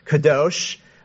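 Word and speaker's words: Kadosh (קדוש): Who wants to take a stab at pronouncing that Hebrew word? Kadosh. Is that what you said? Pretty good. Kadosh.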